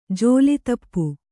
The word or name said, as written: ಝೋಲಿತಪ್ಪು